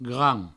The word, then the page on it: gran: Provençal